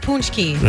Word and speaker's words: Pazcki: Because it's spelled P-A-Z-C-K-I. – Yeah, there's some Z's and stuff in there. – Yeah, but it's pronounced poonski.